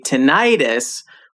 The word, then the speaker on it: tinnitus: Yeah, and I've also heard that British people tend to emphasize the first syllable, but many Americans, including me, say tinnitus with the emphasis on the middle syllable. Anyway, now that we know we can say it either way, Kathy, would you be able to give us a brief explanation of tinnitus?